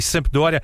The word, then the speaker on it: Sampdoria: Sampdoria, Tá certo.